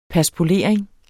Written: paspolering

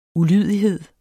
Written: ulydighed

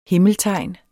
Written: himmeltegn

